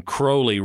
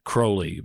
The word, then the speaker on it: Crowley: Crowley rhymes with unholy. And it's Aleister Crowley, but I may refer to him as Crowley because of Ozzy.